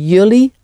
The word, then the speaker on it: jullie: Jullie gaan. It's spelled J-U-L-L-I-E. Jullie. Jullie.